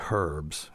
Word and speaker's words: herbs: And then they say herbs instead of, you know, they pronounce the H.